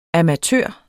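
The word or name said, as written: AMATØRER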